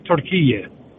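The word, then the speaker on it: Türkiye: In Arabic, the word is Turkiye. It's always been Turkiye. It's never been Turkiye. But because of the phonetically spelling of Turkiye, people weren't emphasizing the iye at the end of it. The name is Turkiye.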